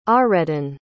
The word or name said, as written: ahreddan